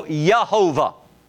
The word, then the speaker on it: Jehovah: J's are pronounced Y. So it's not Yah. So Yehovah would be the proper way to say it.